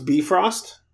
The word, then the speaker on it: Bifrost: So they go, they meet Heimdall on the, what I've always called Bifrost, but in the audiobook they pronounce Bifrost.